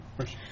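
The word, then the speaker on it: Mercer: One was hired by, I believe, MSRS Mercier. Did I pronounce them right? – Yes, Mercier.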